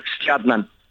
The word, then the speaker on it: Stjarnan: It's called Stjarnan. – Okay, that's pretty good. – STJA, and if you put a little D next to the R, you'll get it right. So Stjarnan.